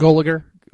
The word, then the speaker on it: Gulager: It's Gulager.